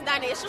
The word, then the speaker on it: Donaysha: My name is Dinesha Schroeder. First name is spelled D-O-N-A-Y-S-H-A. Last name is spelled S-T-R-O-U-G-H-T-E-R.